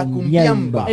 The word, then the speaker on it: cumbiamba: cumbiamba.